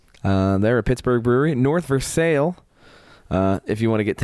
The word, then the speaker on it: North Versailles: Everywhere else in the world, it's pronounced Versailles. Yeah. Here. – Them's the French. – Right. Here in Pittsburgh, it is North Versailles.